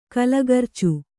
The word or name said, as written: ಕಲಗರ್ಚು